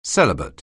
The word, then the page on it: celibate: anglais